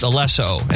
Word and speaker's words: Alesso: Aliso? No, that's the producer. Who's the girl singing? – Oh, I don't know. I thought that was her name. – Oh, no. It's actually pronounced Alesso, and he's just the guy.